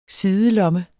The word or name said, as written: sidelomme